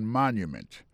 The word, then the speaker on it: monument: Pour mot monument, il tombé sur premier syllabe là. The Washington Monument.